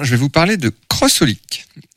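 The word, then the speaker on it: xxxHOLiC: Donc, pour ceux qui ne savent pas que ça se prononce comme ça, parce qu'on n'est pas censé forcément le savoir, sur la jaquette, c'est pas écrit Crossolic, c'est 3X et plus loin, Olic. H-O-L-I-C.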